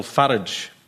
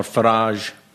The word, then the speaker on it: Farage: It's Nigel Farage, or Farage, however you pronounce it. It's meant to rhyme with wherever you park your car, I believe.